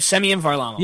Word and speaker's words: Semyon Varlamov: supposing that he can supplant Seaman Verlamov. Ver, Verlamov. Did I say that right? Seaman? Varlamov. V- Seaman Varlamov. My my. – Semyon bad. Semyon Varlamov.